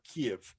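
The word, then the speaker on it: Kyiv: So they have this common history because Ukraine is the cradle, the city Kiev that you keep hearing of, or Kiev uh, in, in the Ukrainian pronunciation.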